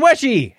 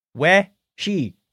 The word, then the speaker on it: Weshy: And Stephen Weshy, happy birthday. Pronounced like the first part of wet and the word she. We-she. Got it. – Yes, that's right.